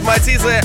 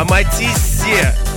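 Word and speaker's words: Matisse: Было бы прикольно поколбаситься внутри лодки с треками от Матиза. От Матиза почему-то. Пишется не Матиз, а Матиссе. И Садко, Мальвина.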